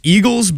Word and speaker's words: Eagulls: It's Eagles, but it's certainly not the Eagles, and it's spelled E-A-G-U-L-L-S.